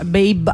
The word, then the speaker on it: Babe: La Righteous Baby, che insomma Babe, anzi dovrebbe essere la pronuncia probabilmente, sì.